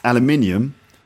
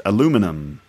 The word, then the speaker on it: aluminium: Or the word aluminium, which in America is pronounced aluminum. Basically, we're talking about modern American influences on British English and how British people feel about that.